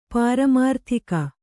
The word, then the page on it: ಪಾರಮಾರ್ಥಿಕ: pāramārthika